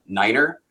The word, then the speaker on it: niner: One of the most famous ones is Niner, which historically, and whether it's true or not, they say, oh, well, the Germans will say nine means no, so let's use Niner.